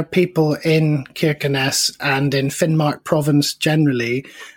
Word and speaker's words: Kirkenes: Yes, Kirkenes. Interestingly, the people there seemed to turn the first K into a sort of a sound a bit like a Russian H. So Kirkenes, many people seem to say, but...